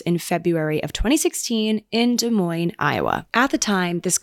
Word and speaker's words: Des Moines: Fun fact, I just learned how to say Des Moines today because I thought that you pronounced the S's this whole time. I thought it was Des Moines.